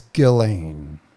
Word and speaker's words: Ghislaine: I think it's Ghislaine.